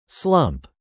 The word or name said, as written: slump